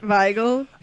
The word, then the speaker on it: Weigel: Weigel, because the W is pronounced like a V, right? – Yeah.